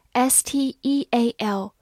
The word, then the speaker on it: steal: 补充一下，它有一个同音词 s t e a l e a 字母组合，同样发长音 e，也读作 steal，但是它是动词，表示偷或者盗。